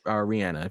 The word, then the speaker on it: Rihanna: It's pronounced uh, Rihanna.